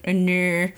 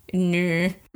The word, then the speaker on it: Unnr: She's called Unnr. Unnr. U-N-N-R. It's transliterated as in English.